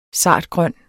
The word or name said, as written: sartgrøn